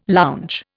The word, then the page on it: lounge: Indian